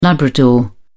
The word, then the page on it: labrador: British English